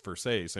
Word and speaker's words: Versace: And she's like, it's Versace.